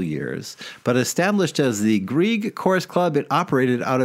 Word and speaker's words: Grieg: Is that, am I saying that right? Grieg? – The Grieg Club.